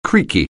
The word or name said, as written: creaky